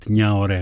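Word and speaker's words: Gnahoré: Mon Village, λοιπόν, το τελευταίο σίγγλ της Ντομπέτ Νιαορέ, γράφεται με GN, έτσι προφέρεται.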